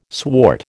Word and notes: swart: American English

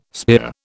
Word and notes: spear: British English

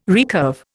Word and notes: recurve: Indian

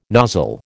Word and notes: nozzle: American English

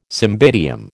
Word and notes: cymbidium: American English

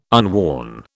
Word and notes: unworn: British English

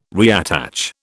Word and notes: reattach: British English

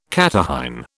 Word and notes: catarrhine: British English

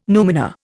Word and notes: numina: Indian

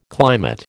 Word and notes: climate: American English

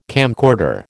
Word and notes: camcorder: American English

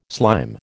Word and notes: slime: American English

